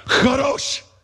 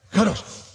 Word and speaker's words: Kadosh: If the person in front of you is not wiping stuff off the back of their hair, you didn't say it right. Kadosh. Kadosh. And it literally means separated.